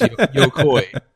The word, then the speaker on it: Yokoi: So his name is Gun Pie, and his last name is Yokoi. But you pronounced it Gun Pie Yoki. I thought his first name was Gun, and his last name was Pie Yoki.